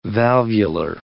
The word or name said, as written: valvular